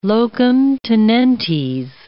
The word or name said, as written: locum tenentes